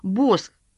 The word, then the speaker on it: Босх: Босх. Похоже на свечи зажигания Бош. Пишется так же, а произносится по-другому».